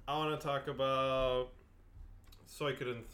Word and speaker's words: Suikoden: I guess in Japanese you would pronounce it Suikoden.